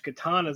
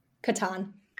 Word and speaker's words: Catan: And I haven't played as much Catan as I used to play. Which, let me step back. How do you say it? The Settlers of... – Catan. – Catan, okay. Yeah.